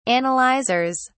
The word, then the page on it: analyzers: British English